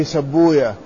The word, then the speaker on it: سيبويه: قال: وقد روينا أن سيبويه، هكذا يعني اشتهر النطق بهذه الكلمة، والمحدثون لا ينطقون هذه الكلمات كما ينطقها علماء اللغة، وإنما ينطقون في مثلها بضم الحرف الأول وتشديد الحرف الثاني وضم الحرف الثالث، فيقولون فيه سبوية في سيبويه، كما يقولون في نفطويه نفطوية، وهكذا كل اسم مختتم بويه لا ينطقه علماء الحديث بذلك.